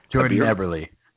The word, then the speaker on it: Eberle: I think it was a barrel. Is it? How do you pronounce it? My pronunciation is garbage. – Jordan Eberle. Eberle. – Like the Eberle brothers.